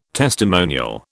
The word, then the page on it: testimonial: British English